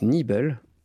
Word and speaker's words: Nebel: Donc ça se prononce Nibel, et c'est de l'allemand.